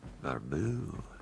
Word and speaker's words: Barbeau: B-A-R-B-E-A-U. – Barbu. Yeah, a little French stuff going on there. – S-C-H-U-Y-L-E-R.